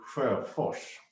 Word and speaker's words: Sjöfors: Well, if you would do it in Swedish, it would be Sjöfors.